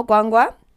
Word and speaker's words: Gwangwa: I actually wanted to give Brian a challenge and like pronounce my surname. Can you? I know you must pronounce it the first day you read it. – No. I wanted to ask, was it Gwangwa? Because I saw a G.